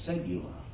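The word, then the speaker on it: segula (סגולה): This treasure box was, here's a big Hebrew word. This is the way it's pronounced. Since you don't know, I don't think, I'm going to say it's the way it's pronounced. His segula.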